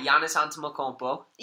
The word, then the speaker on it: Giannis Antetokounmpo: All right, Giannis Antetokounmpo.